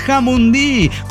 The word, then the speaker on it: Jamundí: Así que llegó para decir, es Jamundí, con acento en la última.